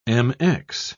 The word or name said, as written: mx